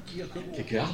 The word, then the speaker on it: Kierkegaard: Et vous, Régis Boyer, en ce moment, c'est Kierkegaard ? – Oui. – Que je dois très mal prononcer en plus, c'est ça ? – Kierkegaard.